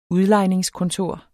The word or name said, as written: udlejningskontor